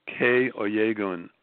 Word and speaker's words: Kay Oyegun: Kay Oyegun, O-Y-E-G-U-N.